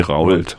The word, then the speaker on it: Rault: R-A-U-L-T, wie Rold.